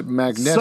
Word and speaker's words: Magneto: No, his so, name is pronounced Magneto.